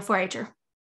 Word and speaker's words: 4-H-er: And we know that you're a 4-H-er. Is that the right way to say it? – Yes, I am a 4-H-er.